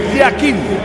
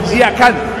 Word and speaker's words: Yakin: En el suizo se pronuncia así: Murat Yakin. En turco, Yakan. Cambia la última parte de su apellido.